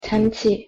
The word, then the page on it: 잔치: Korean